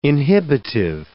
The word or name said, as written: inhibitive